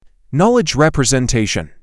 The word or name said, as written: knowledge representation